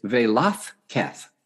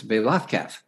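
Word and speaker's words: Velázquez: Well, I looked him up, and there, his actual pronunciation is Velazquez. The Z is pronounced TH, so it's Velazquez.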